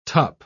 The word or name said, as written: tup